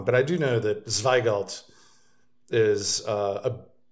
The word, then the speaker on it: Zweigelt: And a grape called Zweigelt.